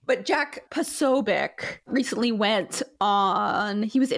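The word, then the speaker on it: Posobiec: According to Wikipedia, it's pronounced Pasobic.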